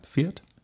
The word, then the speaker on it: Fiat: So, Marcy Robin was buying a Fiat 500X. Did I say that right? Fiat? – Yeah.